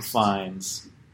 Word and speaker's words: Fiennes: It is pronounced Fiennes, by the way.